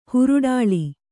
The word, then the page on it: ಹೇರೊಡಲ: hēroḍala